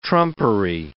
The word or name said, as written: trumpery